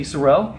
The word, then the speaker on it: Sorrell: Today with us we have Katy, Sorrell.